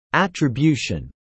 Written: attribution

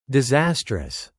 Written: disastrous

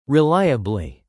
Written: reliably

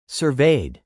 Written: surveyed

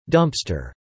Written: dumpster

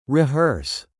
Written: rehearse

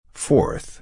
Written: forth

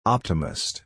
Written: optimist

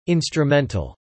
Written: instrumental

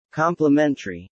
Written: complementary

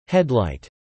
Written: headlight

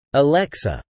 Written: Alexa

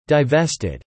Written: divested

